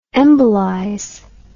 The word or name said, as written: embolize